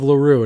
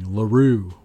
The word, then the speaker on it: LeRoux: If you ever heard of LaRue, and it's spelled L-E-R-O-U-X, in case you're not from the southern part of the United States, it's just a thing. It's that Creole French Louisiana thing, LaRue with an X.